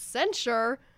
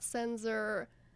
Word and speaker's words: censure: It's censure, censure, not censor, which I know that's not how it's spelled, but that's what I said, and that's my bad, so I'm sorry.